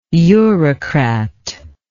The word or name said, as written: eurocratic